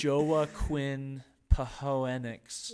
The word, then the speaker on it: Joaquin Phoenix: Joaquin Phoenix. Is that how you say his name? – Sounds right to me.